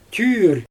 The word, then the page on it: tyyr: Xyzyl